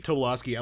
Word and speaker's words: Tobolowsky: Tobolowsky.